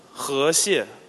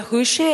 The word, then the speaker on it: Huxie: Maybe if you're Chinese, you can pronounce it better. – Huxie. – Yes, this is the right pronunciation.